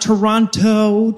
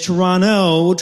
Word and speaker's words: Toronto: That is not Toronto to rhyme with pronto. It is Toronto to rhyme with guano.